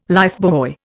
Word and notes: lifebuoy: Indian

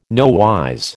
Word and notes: nowise: American English

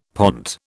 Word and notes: pont: British English

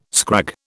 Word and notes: scrag: British English

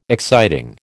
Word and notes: exciting: American English

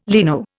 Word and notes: leno: Indian

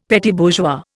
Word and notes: petit bourgeois: Indian

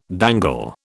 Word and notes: dangle: British English

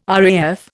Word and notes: RAF: Indian

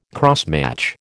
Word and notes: crossmatch: American English

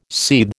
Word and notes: seethe: American English

